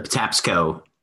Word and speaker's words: Patapsco: How do you spell that one? – P-A-T-A-P-S-C-O. – Patapsco, okay. – Patapsco. – Oh, P-S-C-O. – Yes, P-S-C-O.